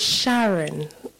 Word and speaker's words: Sharon: My name is Sharon. That's how it's pronounced.